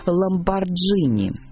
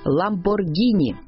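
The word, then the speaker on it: Lamborghini: Привык стоять за себя и наверняка не потерпел бы, если его самого или любимой машины кто-то сгоряча назвал «Ламборджини». Тем не менее, российские фанаты суперкаров зачастую говорят именно так, хотя никаких доводов в пользу произношения через «G» нет. Запомним, по правилам итальянского языка «Ламборгини» и только так.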